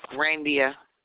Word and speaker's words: Randia: Randia. – Can you spell that for me? – R-A-N-D-I-A.